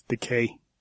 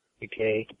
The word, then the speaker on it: Takei: I don't know, I've heard it pronounced both ways. – Yeah, I think it's the way I hear it is Takei. – Takei, yeah, it's pretty.